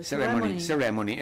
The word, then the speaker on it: Ceremony: Ceremony, Serenia, vabbè, Ceremony. – Giusto, l'hai detto giusto? – Ceremony, esatto, mi inceppano le cose.